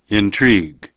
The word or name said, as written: Intrigue